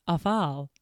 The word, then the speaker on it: offal: It's O-F-F-A-L, but kind of. – That is how it's pronounced. Once again, was always like, it's offal. – Well, I've heard somebody say offal before, but I've always heard it referred to as offal. So don't worry. I went to trusty Google and I pulled a classic You and Me where I went to the video and was pronounce? – Like, awful, awful. I'm like, and it is awful.